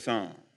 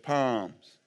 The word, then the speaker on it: Psalms: Turn real quick with me to the book of Psalms. When I first became a Christian, it was the book of Palms. In Alabama, you have pronounced all the letters.